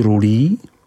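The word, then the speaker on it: Rully: Od obce Buzoron je to jenom malý kousek k prvním vinohradům Rulili, vyslovuj Rulí.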